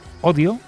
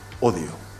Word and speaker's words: Hodio: La herramienta se llama Odio, pero no se escribe normal. – Vamos a poner en marcha la herramienta que hemos llamado Odio. H-O-D-I-O. – Odio.